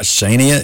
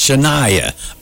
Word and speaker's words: Shania: Until somebody said, it's not Shania, it's Shania.